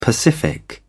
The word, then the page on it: pacific: British English